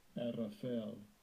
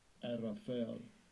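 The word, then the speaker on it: Arafel (ערפל): Let's listen to the pronunciation of this word in the African Hebrew language. – Strong's H6205. Arafel. Arafel. – Arafel.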